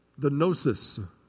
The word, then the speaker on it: Gnosis: There are those things, we call them the gnosis. Starts with a G. The G is silent. Gnosis.